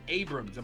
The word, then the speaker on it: Abrams: The reason why I say that's a great segue, Stacey Abrams, am I saying her name right? – Yes.